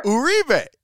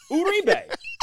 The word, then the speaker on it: Uribe: And I told that to Gene, and he was like, oh, of all the guys, I mispronounced his name the whole time he was here. – Uribe. – Like, Uribe. John Miller had it right.